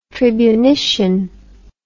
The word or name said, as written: tribunician